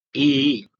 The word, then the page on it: ii: Sanskrit